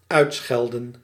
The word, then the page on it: uitschelden: /ˈœy̯tsxɛldə(n)/; /ˈœtsxɛldə(n)/